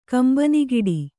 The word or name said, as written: ಕಂಬನಿಗಿಡಿ